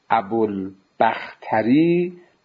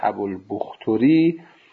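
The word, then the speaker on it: أبو البختري: اون عزیزانی که اول بحث هم نبودن، باز من الان عرض میکنم این تلفظ ابوالبختری به همین شکل صحیحه و ابوالبختری این تلفظ غیر صحیحه و تسامح در اطلاق این راوی هست.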